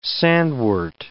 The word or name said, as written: sandwort